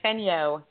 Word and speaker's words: Fenyo: You said it right the first time, Fenyo, so the E is silent.